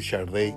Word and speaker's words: Sade: 1959 nace la compositora y cantante nigeriana conocida como, mire, se escribe Sade, pero se dice Sharday, no sé por qué.